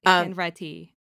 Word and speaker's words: Hanretty: Is that how you say his name? I feel like I'm always butchering it. – It's Hanretty. – Hanretty.